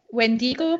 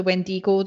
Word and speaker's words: Wendigo: Is that how you say it? Wendigo. – Oh, yeah.